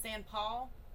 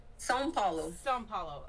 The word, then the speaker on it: São Paulo: I'm going to say this wrong, San Paul? – São Paulo, okay. – That's how it's pronounced.